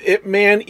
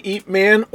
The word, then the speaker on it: Ip Man: After that, and I looked this up, can be pronounced Ip Man, Eat Man, or IP Man. – Right. – All three are acceptable.